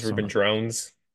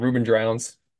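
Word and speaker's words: Ruben Droughns: Ruben Drowns. Drones. I don't know how to pronounce Ruben Drones. I think it's Drones.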